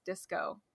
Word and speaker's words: DSCO: And they do confirm that it's pronounced DSCO in their email.